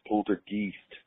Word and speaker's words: poltergeist: So, on the whole poltergeist issue, my sister's a bit of a paranormal buff, and she's told me that poltergeist is actually pronounced in German as poltergeist, and she was telling me that poltergeist. – It's because geist runs with yeast, and she was telling me that like it has to do with like whenever yeast infection crops up in a woman.